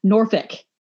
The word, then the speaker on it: Norfolk: It's Norfolk.